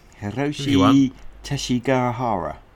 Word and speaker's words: Teshigahara: Shall I have a go? Hiroshi Teshigahara. It's an unusual name, isn't it? There seems to be an extra syllable there you're not expecting.